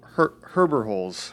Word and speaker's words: Herberholz: I'm sorry, how do you say your last name again? – Herberholz. – Herberholz. – Exactly like it looks, right.